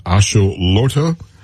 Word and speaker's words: axolotl: It's for the World Cup, it is a salamander and it's pronounced axolotl.